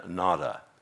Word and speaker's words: nada: Our nada who art in nada. You know, that's very sad, isn't it? Did I pronounce that word correct? Yes. Is it nada? Yes. Yeah.